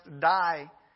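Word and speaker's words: die (δεῖ): The original word in the Greek here is pronounced die.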